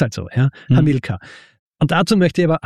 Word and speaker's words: Hamilkar: Meistens bei diesem Konsens ankommen, dass bei Hamilkar wird es eben so basierend auf diesen lateinischen Regeln ausgesprochen. Hamilkar.